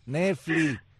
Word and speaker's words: Netflix: Yo no sé por qué ustedes los americanos dicen esas cosas mal. Es Netflix. Netflix.